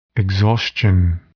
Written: exhaustion

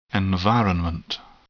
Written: environment

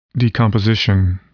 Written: decomposition

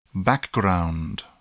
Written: background

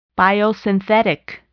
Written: biosynthetic

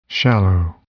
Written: shallow

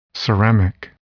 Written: ceramic

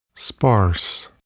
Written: sparse

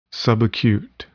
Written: subacute

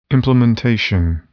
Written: implementation

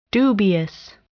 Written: dubious

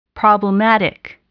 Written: problematic

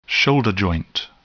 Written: shoulder joint

